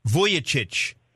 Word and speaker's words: Vujicic: How do you say it? – Vujicic. – Vujicic. Well, that's not the way it's spelled on the little thing I have here. It says Cheech.